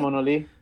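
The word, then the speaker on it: Monolee: Mona Lee. It's a Spanish translation of monolith, which is the town that I was born in.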